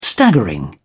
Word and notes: staggering: British English

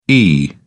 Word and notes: ee: British English

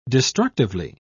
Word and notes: destructively: British English

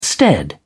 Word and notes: stead: British English

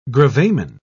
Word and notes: gravamen: British English

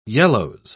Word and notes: yellows: English; British English

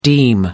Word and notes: deem: American English

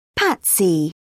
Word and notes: patsy: British English

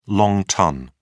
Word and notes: long ton: British English

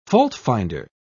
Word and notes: faultfinder: British English

